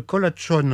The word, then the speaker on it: colachón: Alors, j'ai tendance à le prononcer à l'espagnol, vu le nom. C'est peut-être erroné. Le colachón. Alors, qu'est-ce que c'est comme instrument ? – Alors, moi, je dis colachón.